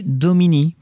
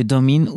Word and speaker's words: Domine: La prononciation correcte du domain du titre est domini plutôt que domine ou domain, justement, comme on peut le constater dans les enregistrements de concerts où Roger Waters annonce le titre de la chanson en disant Astronomy Domini.